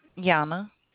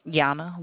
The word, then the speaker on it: Yanna: Yana. – Yama? – Yana. Y-A-N-N-A. – Why? Spell it for me again, dear. – Y-A-N-N-A.